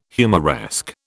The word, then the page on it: humoresque: British English